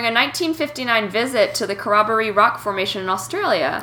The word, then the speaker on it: Corroboree: Oh, it is the Corroboree. – Okay. – Oh, I must be thinking of a different weird Australian pronunciation word.